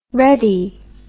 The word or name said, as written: reddy